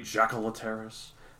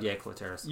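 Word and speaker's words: Jaekelopterus: I'm assuming it's pronounced Yacoloteris. It could be Jacoloteris. – But Yacoloteris. Yeah, because like Germanic words beginning with the J make the Y sound. You're like Ja, Jaeger, Johann.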